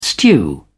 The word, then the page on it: stew: British English